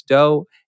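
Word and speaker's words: Do: And my name is Chris Doe. And you can find me on Instagram at the Chris Doe. That's spelled D-O and everything else is spelled like a normal person.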